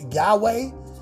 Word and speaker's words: Yahweh: the letters YHWH, which came to be pronounced Yahweh, is the written name of God in the ancient Hebrew written language, which did not have vowels.